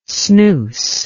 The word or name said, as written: snoose